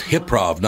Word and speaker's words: Hip-Prov: Oh, so it's pronounced Hip-Prov, not High-Prov. – Yeah, Hypnosis-Prov. – That's right. – Hip-Prov, Hypnosis, yes. Ladies and gentlemen, Asad Meki, Colin Mockery, Hip-Prov with Colin Mockery and Asad Meki.